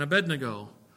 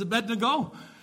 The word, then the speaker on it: Abednego: He had three friends with him, Shadrach, Meshach, and Abednego.